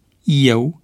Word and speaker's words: eu: Astfel se rostesc cu IE, la inițială, pronumele personale: eu, el, ei, ele și formele verbului a fi: ești, erați, e, eram, erai, era, erați, erau. Greșeala de pronunțare cu E deschis în loc de IE se datorează probabil unor tendințe de hipercorectitudine,